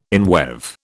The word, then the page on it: inweave: British English